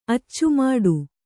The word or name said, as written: ಅಚ್ಚುಮಟ್ಟ